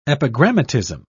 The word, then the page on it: epigrammatism: British English